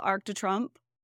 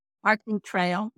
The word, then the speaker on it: Arc de Triomphe: Arc de Triomphe. I didn't say that right. – Arc de Triomphe.